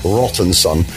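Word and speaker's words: Rattanson: Rottenson.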